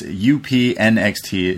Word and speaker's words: UPNXT: UPNXT is how we pronounce it.